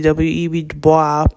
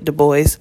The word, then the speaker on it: Du Bois: And so in learning about W.E.B. Du Bois, or in French it would be W.E.B. Du Bois, but he was a Louisiana Creole, so he spoke English, so he pronounced it Du Bois.